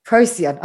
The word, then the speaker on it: Procyon: And then I think that confirmation can be really empowering because I was like, okay, so I'm from this, I think it's what mine said was Procyon, if I've said that right. – Procyon? – Procyon. Oh, there you go.